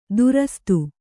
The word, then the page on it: ದುರಸ್ತು: durastu